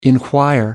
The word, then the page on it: enquire: American English